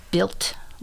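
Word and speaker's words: Bild: I'm trying to say it properly like German. It's B I L D. Okay. Lily. But when I do the little mm-hmm. translation thing, it's very much like built, kind of like a T Yeah, they almost.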